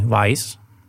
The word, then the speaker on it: Whys: Honza je founder a ředitel technologické společnosti Vice, jestli to dobře vyslovuju. – Ano. – Honzo, co, co v svým pojetí znamená technologická firma a abych něco nesplet, eh, představ nám trošku Vice.